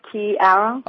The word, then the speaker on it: Kiara: It's like the key in the door, Kiara.